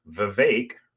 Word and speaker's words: Vivek: We have businessman Vivek, rhymes with cake, Ramaswamy.